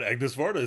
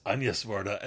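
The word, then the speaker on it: Agnès Varda: And it's not Agnes Varda, it's Agnes Varda.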